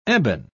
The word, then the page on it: ebon: British English